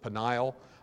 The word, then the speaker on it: Peniel: One word calls it Peniel, or some people pronounce it Peniel. Notice the E-L on the end of it, having to do with God.